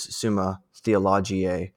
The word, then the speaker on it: Summa Theologiae: So I guess if you were to go to Wikipedia, it's actually Summa Theologica, but it also says another way you can pronounce it is Summa Theologiae, I forget how you'd pronounce this in Latin. But anyways, it does go both ways. I think the standard way you normally say it is Summa Theologica or some variant with a C at the end.